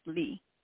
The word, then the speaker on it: Lee: Yes, Lee. – Can you spell that, please? – That's L-E-E. – L-E-E.